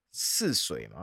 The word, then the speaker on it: 泗水: 哈，这名男子是来自印尼的第二大城，叫做泗水嘛，嗯、这个字怎么念呢、啊？